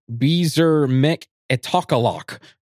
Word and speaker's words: Bzermikitokolok: I wrote it down on how to pronounce it because it's spelled BZERMIKITOKOLOK. – What? – And it's pronounced Beezer Mech Etokalok.